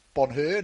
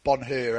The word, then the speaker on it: Bonheur: Dino, a number of our audience like to make sure we get the horse's pronunciation correct, and I'm informed that the H in number 13, Bonheur, is silent. So it is pronounced Bonheur, not Bonheur.